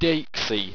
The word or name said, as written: deixe